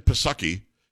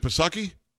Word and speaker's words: Psaki: Uh, she's on with Jen Psaki. Is it Psaki? – No, the P is silent.